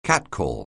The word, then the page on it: catcall: anglais